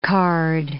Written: card

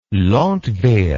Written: landwehr

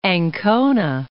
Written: Ancona

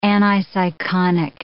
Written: aniseikonic

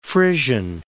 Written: Frisian Islands

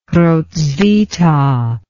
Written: Hrotsvitha

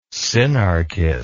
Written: sinarquist

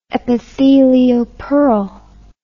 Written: epithelial pearl

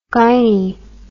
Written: gynie

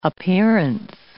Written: appearance

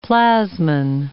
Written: plasmin

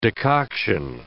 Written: decoction

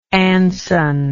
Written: anson

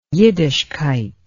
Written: yiddishkeit

